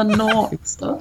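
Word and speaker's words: nor'easter: Oh, the Northeaster. – Exactly. We don't pronounce the TH, but thanks. The Northeaster.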